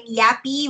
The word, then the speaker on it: Yapi: So on Twitter, I'm Yappy, which is Y-A-P-I. So my ring name is spelled Y-A-P-P-Y, but my Twitter is how my name outside the ring is spelled.